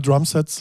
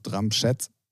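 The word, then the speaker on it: Drumsheds: Ja, Drums Heads. – Drums Gets. – Nee, Heads. – Drums Heads.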